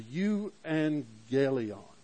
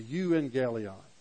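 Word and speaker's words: euangelion (εὐαγγέλιον): The euangelion. The euangelion.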